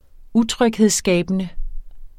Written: utryghedsskabende

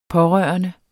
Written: pårørende

